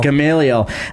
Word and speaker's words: Gamaliel: Gamaliel. – Gamaliel.